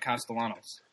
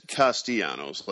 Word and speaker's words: Castellanos: Well, and it's Castellanos. Let's start you there. – All right. So, I didn't get very far. – It's the most commonly mispronounced name on the Tigers roster this side of Anibal.